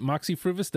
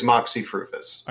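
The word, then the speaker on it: Moxy Früvous: Is it Moxie Fruvis? Did I say it correctly? – You did. You did excellently. Thank you. – There we go. – It is Moxie Fruvis.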